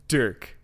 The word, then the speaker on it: Dirk: Dirk. – Like Dirk. Dirk the Daring? – Like rhymes with jerk. – Is it D-E-R-K? – D-I-R-K.